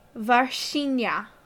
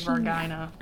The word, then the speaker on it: Varginha: Varginha. – Varginha. I thought it was really Vargina. – To be fair, every time I was reading it, you know, I'm doing the American English pronunciation thing.